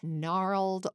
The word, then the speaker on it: gnarled: As he rolled along, he met gnarled old paper scraps. What? A silent G?